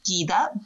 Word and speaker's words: Guida: My name is Guida Botello. So the U is silent there. I'm Portuguese, not Italian.